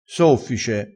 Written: soffice

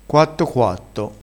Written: quatto quatto

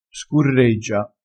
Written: scurreggia

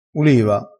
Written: uliva

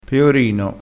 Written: peorino

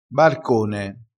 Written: barcone

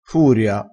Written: furia

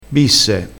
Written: bisse

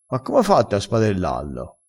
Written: spadella

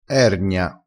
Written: ergna

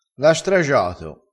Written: stragia

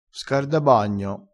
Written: scardabagno

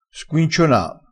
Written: squinciona

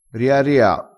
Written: riaria

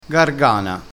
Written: gargana

